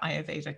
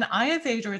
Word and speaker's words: Ayurveda: So it's I, meaning your eyes, your, meaning me and you, your, Veda. These are the scholarly scripts that Ayurveda comes from. And Ayurveda is a constitutional system of medicine that classifies people into categories.